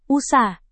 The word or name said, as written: usah